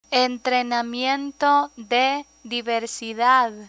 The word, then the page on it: entrenamiento de diversidad: Spanish